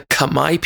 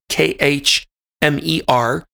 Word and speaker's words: Khmer: Forrest chose one of them in there, and that's the Khmer people, K H M E R. It looks like it would be pronounced Khmer, but they've told me it sounded it sounds like Khmer.